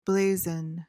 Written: blazon

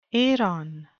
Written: eiron

